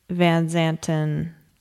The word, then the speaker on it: Van Zanten: When the control tower gave Van Zanten, is that how you pronounce it? – Mm-hmm.